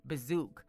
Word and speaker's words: Bizook: And it's spelled B-I-Z-O-O-K. That's B-I-Z-O-O-K, Bazook.